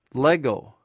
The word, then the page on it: legw: greek